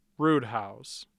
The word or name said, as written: Roodhouse